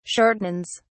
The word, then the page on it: shortens: British English